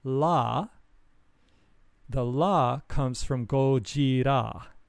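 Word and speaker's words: Gojira (ゴジラ): So in Japanese, Godzilla is pronounced Gojira.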